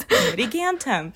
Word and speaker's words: Pamitigantum: And I think that's what I've noticed is like all our names, when they've been made into the English versions, they are literally spelled the way that they should be pronounced. Like, whenever I like say out my last name, like Pamitigantum, people are like, oh, so it's just like how it's spelled.